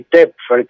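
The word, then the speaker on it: Töpffer: El, apellido es alemán, así que se debe pronunciar con dieres y Tepfer, creo.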